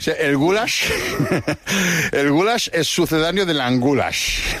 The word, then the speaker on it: goulash: Es el goulash es pronunciado así, goulash.